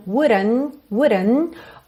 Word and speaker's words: wouldn't: Essa letra D foi americanizada, porque esse é um padrão típico da pronúncia americana, que é rrrr, por isso está pintadinha de azul claro. Então, wouldn't. O T, consoante oclusiva aqui também, quase não aparece.